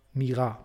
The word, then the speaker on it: Mira: Ich bin Mira Kim, aber Mira, Betonung auf der zweiten Silbe, bitte.